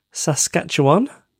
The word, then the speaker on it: Saskatchewan: She and I work together as a therapy dog team here in Saskatchewan in Canada.